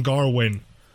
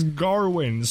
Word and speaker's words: Gawain: I'm pretty sure it's pronounced Gavin. – I think that's the Welsh pronunciation, but continue. – Yeah, I'm not totally sure why they call him Garwin. It does not really bother me at all. – Mm-hmm. – it didn't bother me at all. – Wait. But it isn't... isn't that like an Old English thing where the W is pronounced as a V? – No.